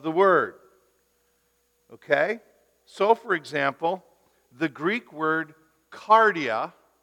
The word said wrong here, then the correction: cardia: means heart, or cardia, pardon me. Sorry, I didn't mean to mispronounce it.